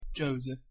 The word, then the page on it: Joseph: English